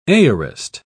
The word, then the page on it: aorist: British English